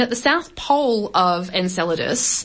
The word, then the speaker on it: Enceladus: Some people pronounce it Enchilada, but. – Sure. – Yeah, Enceladus. – Enceladus.